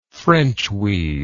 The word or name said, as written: frenchweed